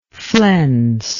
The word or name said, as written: flenser